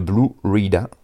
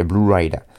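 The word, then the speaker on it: Blu Ryda: Et il y a deux prods de Blue Rida. Donc je sais pas comment ça se prononce, tant pis. Blue. Ouais, si, BLU, plus loin RYDA. Alors, si je le prononce à l'américaine, ça fait Blue Rider.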